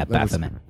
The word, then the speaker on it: Baphomet: Baphomet? – Baphomet. – Yeah, Baphomet. – Yes. – I wasn't sure if the PH combined there, but I suppose it does. – Baphomet? – that's how I was reading Baphomet.